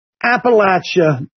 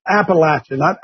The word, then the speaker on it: Appalachia: Appalachia, that is the correct pronunciation, by the way. Appalachia, not Appalachian, Appalachia.